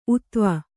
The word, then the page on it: ಊತ್ವ: ūtva